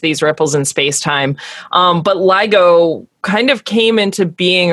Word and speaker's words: LIGO: Explain how something like the arms of the is it LIGO? – Mm-hmm.